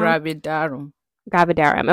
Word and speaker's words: gravidarum: And I think she had like HG, which is hyperemesis gravidium, something like that. Am I saying it right, Mom? – Gravidarum.